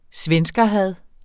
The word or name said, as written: svenskerhad